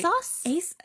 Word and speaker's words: ASOS: Most of the clothes in Bat that you see are actually bought on, like, ASUS. I don't know if I'm saying that right. – ASOS? – ASOS? – ASUS. – ASUS. – It's an O. Not a U.